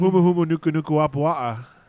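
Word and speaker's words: Humu Humu Nuku Nuku Wapawa (humuhumunukunukuāpuaʻa): Humu Humu Nuku Nuku Wapawa.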